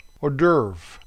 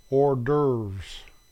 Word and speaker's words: hors d'oeuvre: The American pronunciation is hors d'oeuvres.